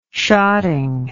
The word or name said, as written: shotting